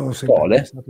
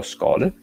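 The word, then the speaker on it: Scholae: Vi è Salvini che non è d'accordo per questo Ius Scolae, non so bene come si pronuncia. Com'è? – Scole. – Allora dirò Scole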